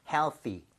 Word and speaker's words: healthy: C가 아니라 윗니와 아랫니 사이 혀가 살짝만 끼는 느낌 그죠. 그게 TH 사운드니까 healthy 정도로 발음하셔야겠고요.